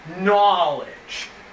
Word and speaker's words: Knowledge: Bad starts with a silent K. Knowledge.